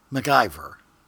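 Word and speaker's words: MacIvor: I do the show notes and it was spelled MAC IVOR. How would you say that? – MacIver.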